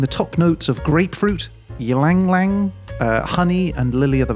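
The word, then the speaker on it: ylang-ylang: Yeah, ylang ylang.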